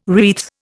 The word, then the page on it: wreath: Indian